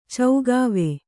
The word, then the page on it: ಚೌಗಾವೆ: kannada